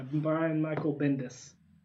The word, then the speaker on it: Brian Michael Bendis: Yep, by Brian Michael Bendis. I said it right that time.